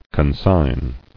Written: Consignation

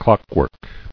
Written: Clockwork

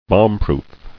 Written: Bombproof

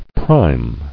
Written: Primeness

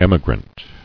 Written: Emigrant

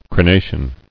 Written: Crenation

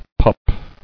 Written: Pupping

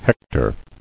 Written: Hectoring

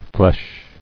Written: Fleche

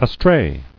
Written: Astray

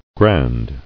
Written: Grandly

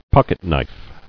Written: Pocketknife

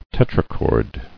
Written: Tetrachord

